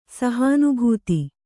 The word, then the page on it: ಸಹಾನುಭೂತಿ: sahānubhūti